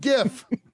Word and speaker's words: GIF: No, it's a hard G sound. It's a fucking GIF.